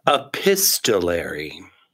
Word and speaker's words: epistolary: Epistolary. – Yeah. So it is. – The T is pronounced.